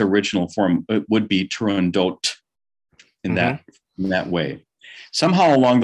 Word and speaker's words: Turandot: It could be pronounced either way, and either way is acceptable. But I'm on your podcast right now, and I'm telling you it must be pronounced Turandot.